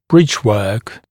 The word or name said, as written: bridgework